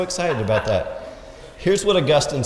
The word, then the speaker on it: Augustine: Augustine, that is the correct way to pronounce it. Augustine is a grass, Augustine is the patriarch.